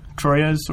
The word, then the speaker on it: Troyes: Troyes or Trois, depending on how you pronounce it.